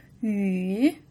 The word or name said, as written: yu3